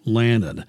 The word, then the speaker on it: Lannan: Lannan. And my last name is spelled L-A-N-N-A-N.